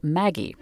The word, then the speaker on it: Maggi: Not just Bovril and Liebig's extract of meat, but also another one that's really popular today called Maggie. At least that's how we're going to pronounce it. There are some places in the world where it's pronounced Maggi.